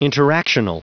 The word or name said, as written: interactional